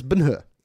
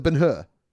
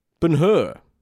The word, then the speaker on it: Ben-Hur: One thing I will point out about this film is that we're pronouncing it wrong. Because about 70 minutes into the film, they say his name for the first time, and it's Ben-Hur. It's like Ben-Hur. – Ben-Hur. – Ben-Hur. So I guess we should call the movie Ben-Hur.